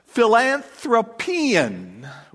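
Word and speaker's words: philanthropian (φιλανθρωπίαν): Now, the word kindness here this is how it's pronounced philanthropian, which sounds a lot like philanthropy, which, by the way, is what that word is. That's where we get it from in the Greek.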